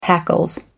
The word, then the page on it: hackles: American English